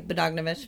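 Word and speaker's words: Bogdanovich: So Stratten started having an affair with the director of the film, Peter um, Badogovich, Do you know who that is? Uh, yeah, you're saying that correctly. Badogovich.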